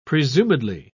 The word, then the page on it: presumedly: British English